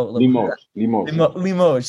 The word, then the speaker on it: Limoges: You call it a case from, I don't know if I'm pronouncing this right, Limoges? I don't know. – Limoges. – See, I knew I was going to pronounce the French wrong.